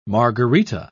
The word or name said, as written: margarita